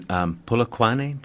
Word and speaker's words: Polokwane: We'll be in Polokwane. Did I say it right? – That's right. Exactly right.